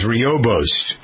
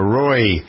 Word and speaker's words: rooibos: It's called red tea, also known as rooibos or roy. Rooibos, R-O-O-I-B-O-S, pronounced either way, has a lot of health benefits.